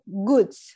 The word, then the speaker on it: Goodz: Eh, eh, goods, así lo pronuncias, ¿verdad? – Sí.